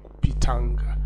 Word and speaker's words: Pitanga: And there's a village in that place called Pitanga. In the local dialect, it is pronounced Pitang. But in English, it is written Pitanga.